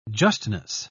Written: justness

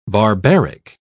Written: barbaric

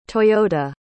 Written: toyota

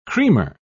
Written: creamer

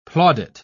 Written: plaudit

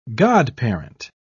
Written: godparent

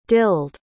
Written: dilled

